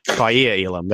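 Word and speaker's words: Kaiir Elam: But Kellar Elam, probably not pronounced that correctly, Kaiya Elam, there we go.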